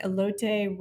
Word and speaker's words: elote: Am I saying that right? Elote ramen bowls. – Elote, yeah.